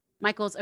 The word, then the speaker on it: Michaels: Michaels.